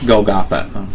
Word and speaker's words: Golgotha: It's Golgotha. – It is Golgotha.